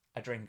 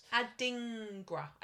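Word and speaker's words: Adingra: A dingra, I think. – A dingra. – It's no R after the D.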